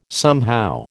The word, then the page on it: somehow: American English